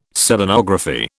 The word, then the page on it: Selenography: British English